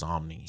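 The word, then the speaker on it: Samnee: If you guys haven't read it, it's Chris Samney and Robert Kirkman. Mm-hmm. Yeah, Robert Kirkman, Chris Samney, and it's... – I think it's pronounced Somni. – Somni.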